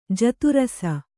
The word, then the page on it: ಜಾತರಾಸಿ: jāta rāsi